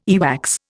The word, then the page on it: earwax: Indian